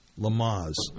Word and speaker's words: Lamaze: Lamaze. – Mm-hmm. – Did I say that right? – Yep. – Lamaze? – Mm-hmm.